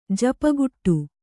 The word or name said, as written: ಜಪಗುಟ್ಟು